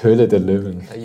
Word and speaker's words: Höhle der Löwen: Höhle der Löwen.